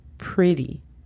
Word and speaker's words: pretty: Pretty. La palabra pretty en inglés se escribe P-R-E-T-T-Y. Cuando yo digo pretty, convierto esas T's en suaves y parecen casi las D's de dedo.